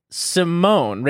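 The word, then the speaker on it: Symoné: Believe it's Simone, Raven Simone. – Because she doesn't have an accent over the E.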